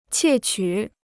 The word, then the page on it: 窃取: qiè qǔ